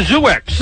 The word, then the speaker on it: Zoox: It's a Zoox.